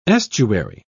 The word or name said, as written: estuary